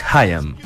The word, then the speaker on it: Haim: Qué difícil pronunciar. – Yo encuentro que es Haim.